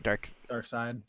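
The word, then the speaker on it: Darkseid: It's got, I think it's Dark Seed. Is that how you say it? – Dark Side.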